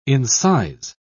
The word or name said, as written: incise